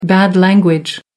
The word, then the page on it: bad language: American English